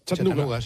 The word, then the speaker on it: Chattanooga: ¿Y se pronuncia así en Chattanooga? – Chattanooga, sí.